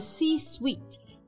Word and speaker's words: C-suite: right，那其他主要的高管呢，还包括 COO Chief Operating Officer，就是运营总监；CFO Chief Financial Officer，就是财务总监。虽然我一个都不是啊。And altogether, they are called the C-suite.